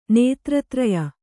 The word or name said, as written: ನೇತ್ರತ್ರಯ